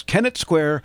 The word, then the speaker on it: Kennett Square: But it's Kennett Square, Pennsylvania, is participating with the Ask Dave button.